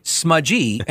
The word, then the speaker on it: Smuggy: And it's spelled Smuggy, but pronounced Smudgy.